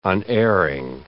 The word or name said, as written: unerring